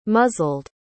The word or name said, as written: muzzled